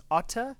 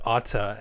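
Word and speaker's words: Otte: Against the German, how do you pronounce his last name? Is it Ott? Otta? – I think it's Otta.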